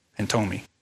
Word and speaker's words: pantomime: was only acting a part in a heartless panatome. Pantomime. Anybody pronounce that one? Pantomime, thank you.